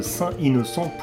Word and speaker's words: Saint Innocent: Le premier était, alors ça dépend si on le prononce à l'américaine euh, Saint Innocent. Saint Innocent, ou si on le prononce à la française Saint Innocent.